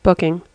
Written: booking

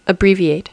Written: abbreviate

